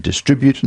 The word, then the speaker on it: distribute: And, you know, it's distribution and distribute and so on, not with a stress on the first syllable, but the BBC's been doing it as well.